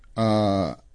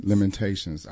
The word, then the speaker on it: Lamentations: Lamentians 3:22-23. Because of the Lord's great love, we are not consumed, for his compassion never fails. They are new every morning. – Lamentations.